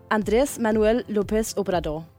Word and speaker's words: Andrés Manuel López Obrador: Han hedder Andrés Manuel López Obrador. – Det var rigtigt. – Okay, super. – Ja, den er godkendt.